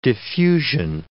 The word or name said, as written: diffusion